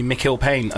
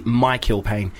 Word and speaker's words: Mikill Pane: That's why I can relate, and that's why I apologise to you, Nevena, for pronouncing your name Navina, because people do call me Mikil Payne a lot, and it's actually Michael Payne.